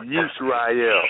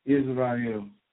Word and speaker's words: Yisrael: But thou, Yisrael, that's how it's pronounced in Hebrew. It's Yisrael. – Israel. – Yisrael. It's three syllables.